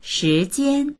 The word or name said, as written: shi2jian1